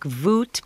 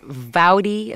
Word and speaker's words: vout: Now, some of these words that we're hearing, or we will hear in these songs, like voot, but sometimes they pronounce it vouty, V-O-U-T, voot, vooty, but sometimes they rhyme it with vouty.